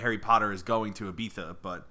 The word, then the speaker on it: Ibiza: It's spelled I-B-I-Z-A, but it's pronounced Ibiza.